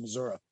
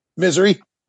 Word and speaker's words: Missouri: Um, they the I love that you say it like a local too. You don't say Missouri. You you said Missouri, and I know yeah. it was spelled like that back previously, but we just call it Missouri, and we're good.